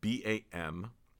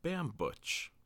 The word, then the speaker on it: Bambuch: I'm going to spell it out for you. Bambuch. That's M as in movie, not N as in narc. And the associate at the camera store asked me to say my last name. And I said Mike Bambuch. And that's actually kind of wrong.